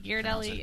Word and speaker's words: Ghirardelli: So we left, and I looked down the block, and there's a Ghirardelli store. Is that how you say it? – Yeah. – Ghirardelli, yeah.